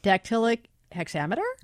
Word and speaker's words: dactylic hexameter: So the Odyssey is a 12,110 line epic poem originally written in, and I hope I get the pronunciation here correct, dactylic hexameter? – Yes. – Oh, well done.